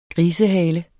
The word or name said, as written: grisehale